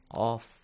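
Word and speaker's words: قاف: Qaf. Qa. It's a heavy letter. Qul. Especially when it has a fatha or an alif mad with it. Qa. Qa. Qa. – Yes, right? – Yes. – It has to be pronounced heavily.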